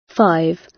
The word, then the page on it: five: British English